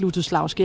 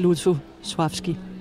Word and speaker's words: Lutosławski: Ja, altså de fleste her på bagscenen siger faktisk Lutosławski, har jeg lagt mærke til. Men altså når jeg er i selskab med polakker, så siger jeg det i hvert fald ikke. Fordi der hedder det Lutosławski. – Der er den der lille streg over det andet L, som betyder, at man skal udtale det mere som V.